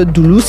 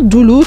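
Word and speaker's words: Dulu: dire que Doulou, c'est l'application qu'il vous faut.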